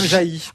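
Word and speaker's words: Jaÿ: de Madame Jay. Ou Madame Jaï, pardon.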